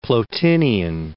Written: Plotinian

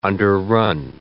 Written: underrun

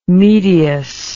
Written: medius

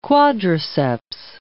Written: quadriceps

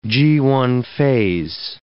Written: Ga1a phase

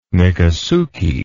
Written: Miccosukee

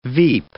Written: veep

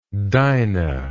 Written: dinah

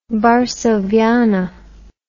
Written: varsoviana

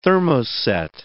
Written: thermoset

